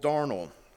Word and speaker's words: darnel: It is believed, if you look at the translation, what the tares are is called darnel. And if you, the pronunciation said that it rhymes with carnal, so it's pretty easy to remember.